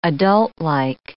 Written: adultlike

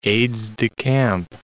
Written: aides-de-camp

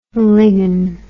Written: lign

